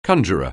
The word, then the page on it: conjurer: anglais